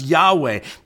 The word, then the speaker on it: YHWH: That's why if you're reading through the Bible, through the Old Testament, you'll see sometimes a bunch of consonants pushed together. Y-H-W-H. It's pronounced Yahweh, but that's why.